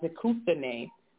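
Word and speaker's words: dikousthene (δικαιοσύνη): If you look up the Greek word, it's pronounced dikousthene, which is the character or justification of a person, righteousness.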